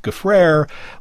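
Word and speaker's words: Gfrörer: It is spelled G-F-O with an umlaut, R-E-R. That's G-F-O with an umlaut, R-E-R, but it's pronounced Gaffrere like despair.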